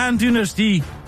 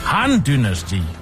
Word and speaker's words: Han-dynasti: hvis Handy, dynasti Handy, dynasti Handy, dynasti Nej, det er Han-dynasti. Han-dynasti.